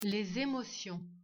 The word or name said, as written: l'eau